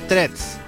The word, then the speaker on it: Threads: ¿Threads? – y dice que se pronuncia threads.